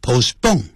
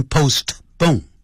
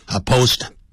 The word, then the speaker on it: postpone: 好，接着我们看第二短语动词 put off, 表延后拖延，以及物动词 postpone, 好，那个 T 打出来了，但实际念的时候呢 T, 它不念出来 postpone, 这样子，不要念成 postpone, 啊 postpone, postpone、你这样读不对，是那个 T 实际的时候它是去掉的 postpone 或是 delay 它同意、意思相同。之后可接动名词或名词做宾语，我们看这个例子。